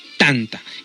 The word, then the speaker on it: tanta: Tanta, que se pronuncia con la primera sílaba en el paladar, y es el pan común.